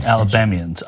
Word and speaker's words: Alabamians: These are Trump supporting Alabamans that, did I say that right? Alabamans? – Yeah. – Alabamians. – Alabamians, yes.